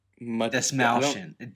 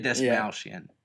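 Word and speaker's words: Dastmalchian: That's funny that you brought that up because I had something about, I don't know how to pronounce his last name, but David Dast... – Desmalshian. David Desmalshian. Yeah.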